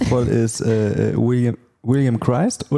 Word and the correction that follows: Williams Christ: Williams Christ, ja.